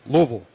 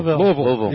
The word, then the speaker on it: Louisville: It's not Louisville. It's Louisville. – Louisville. – You've got to pronounce it correctly.